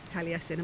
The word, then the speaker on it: Taliesin: Yeah, we found ourselves walking up this steep little river to the what is known as Taliesin's grave, Taliesin, am I saying that right? – Taliesin. Yeah.